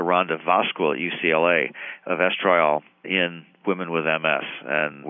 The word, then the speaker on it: estriol: One of the candidates as an immunosuppressive substance produced during pregnancy is one of the estrogens called estriol, E-S-T-R-I-O-L. Some people pronounce that estriol.